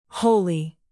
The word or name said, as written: holy